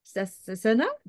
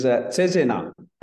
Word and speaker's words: Cesena: Cesena? Did I pronounce that correctly? – Yeah, almost. It's Cesena.